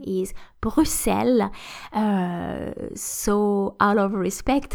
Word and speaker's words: Bruxelles: Uh, because of this X, actually there are um, kind of two different pronunciations, so Bruxelles and also Bruxelles, Bruxelles,